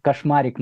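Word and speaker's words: Kacsmaryk: Казмерик, или он по-русски его фамилия читается как кошмарик на самом деле.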